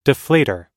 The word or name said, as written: deflator